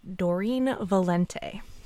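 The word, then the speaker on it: Doreen Valiente: So its diverse practices were drawn from numerous pagan rituals, and they were organized by Gardner himself and a person named Doreen Valente. I think I'm pronouncing that right. – You are.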